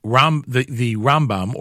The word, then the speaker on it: Rambam: Traditionally, we pronounce Rabbi Moshe ben Maimon's initials as Rambam with the emphasis on the first part of the word, and Rabbi Moshe ben Nachman's initials Ramban with the emphasis on the second part of the word, so as to avoid confusing. The 21st day in the month of Teves, the yard site of Shimon ben Yaakov Avinu from last week's Parsha.